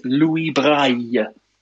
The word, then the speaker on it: Louis Braille: If you are speaking French, his name pronounced correctly is Louis Braille.